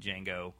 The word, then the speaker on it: Django: the D is silent Django, like, outfit, like the frills.